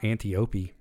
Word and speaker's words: Antiope: Supporting roles, Robin Wright as Antiope. I don't remember how to pronounce her name. I know it's not Antiope. – It is Antiope, actually. – Is it really? – Antiope!